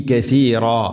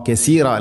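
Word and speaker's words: കഫീറ (كثيرا): മഥല മഥലൻ യുദില്ലു, യുദില്ലു ബിഹി കഫീറ. യുദില്ലു വാദിൻ്റെ ഉച്ചാരണം ശ്രദ്ധിക്കുക. യുദില്ലു ബിഹി കഫീറ, കസീറ അല്ല കഫീറ.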